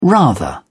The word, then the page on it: rather: British English